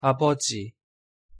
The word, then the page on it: 아버지: Korean